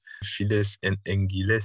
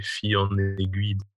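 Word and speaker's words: Fille en Aiguilles: Files en Anguilles, c'est fille en aiguille.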